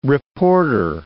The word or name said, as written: reporter